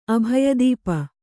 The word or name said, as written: ಅಭಯದೀಪ